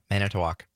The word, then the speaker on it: Manitowoc: Yeah, Manitowoc.